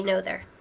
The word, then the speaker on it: Noether: We're talking about the woman you've never heard of, Emmy Noether. – Noether, is that right? – That's the German pronunciation, but we can just say Noether.